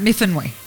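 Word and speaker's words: Myfanwy: You know, Mifamwi always rings a bell to me when you say Mifamwi. – I think you pronounce that Mifanwi.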